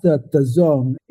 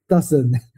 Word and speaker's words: DAZN: It's pronounced the zone. You know how in sports, there's this like a metaphor of you're in the zone where, you know, you're completely focused. Now you're at the top of the game. That's what the DAZN is supposed to pronounce, the zone. But it's so bizarre. Most people in the company pronounce it wrong. We have a lot of engineers call it dozen. – Okay. – But yeah, don't worry. Yeah. It's called the zone.